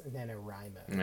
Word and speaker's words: NaNoWriMo: I said NaNoWriMo for the longest time, but apparently someone corrected me, and the actual pronunciation is NaNoWriMo. – Yeah. – Which makes sense, because that's, like, it's abbreviating right.